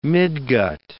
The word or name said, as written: midgut